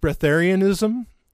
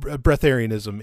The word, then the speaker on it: breatharianism: And so what this person's asking about is, I think it's pronounced breatharianism. Okay. Not as in like getting a breath of fresh air, but it's called breatharianism.